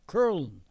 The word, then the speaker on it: Köln: Because the German word is K-O-U-M-L-A-U-T. Köln is the way they pronounce it.